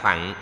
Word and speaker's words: thặng: Chữ đại là lớn, chữ thừa là cởi. Đọc chữ thặng nghĩa là cái xe. Theo đây phải đọc là thặng mới đúng, song vì theo thói quen nên đọc là thừa.